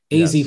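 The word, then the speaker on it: Azzi: So I guess it must be pronounced Azzy. Azzy, yes.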